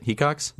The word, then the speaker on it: Hecox: Yeah, and that's why we have Ian Hecox on the podcast. That's how you say it, right? Hecox? – You said it right.